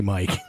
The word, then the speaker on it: Miike: It's M-I-I-K-E. Yeah. So Takashi, I said it Mike's, and Derek corrected me. – And how do you supposed to say it? – I said Miike. I said Takashi Miike.